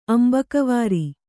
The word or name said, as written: ಅಂಬಕವಾರಿ